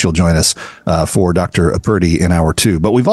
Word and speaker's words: Purdy: In hour two, Dr. Laura Purdy, P-U-R-D-Y, not pretty. I'm not mispronouncing pretty, although she is lovely.